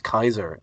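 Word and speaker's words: Caesar: And I mean, there is a history of it, you know, or Julius Kaiser, to pronounce his surname properly.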